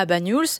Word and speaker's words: Banyuls: Comment on le prononce? – Banyuls. Oui, ouais. C'est ça.